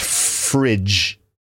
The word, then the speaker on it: frig: So the abbreviated version of refrigerator was originally spelt F-R-I-G, but probably pronounced fridge. But the thing is, when you see the word F-R-I-G, you would probably pronounce that frig. Okay, so the word, the original abbreviation from the 1920s was F-R-I-G,